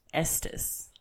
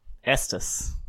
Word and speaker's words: Estes: It's like the E and the second E is supposed to be pronounced like an I, not an E. And I kept saying Estes. – Ah. – But I'm wrong.